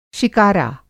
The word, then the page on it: शिकारा: شِکارا